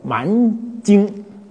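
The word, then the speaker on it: 蔓菁: 第三个还有个音几乎不用，偶尔有人用，它叫什么呢？叫蛮精。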